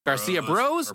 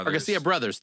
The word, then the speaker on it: Garcia Bros: I don't know if I should say Garcia Bros or Garcia Brothers. They are brothers. – They are brothers. – The three Garcia brothers that own the place. – Abbreviated. – But it is spelled like B-R-O-S. So yeah. I don't know. I think either way is fine.